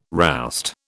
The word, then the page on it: roust: British English